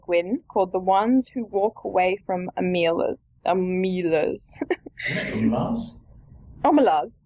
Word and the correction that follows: Omelas: I think I wrote how it was meant to be pronounced. Hang on. How did I write it? Omelas. You're right. Omelas.